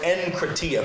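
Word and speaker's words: enkrateia: Now, the Greek word translated into English as self-control is the Greek word enkratia.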